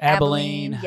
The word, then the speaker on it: Abilene: When you go Amarillo and Abilene. Yes. I would love to know how you actually were about to pronounce that. – I was going to say exactly like that.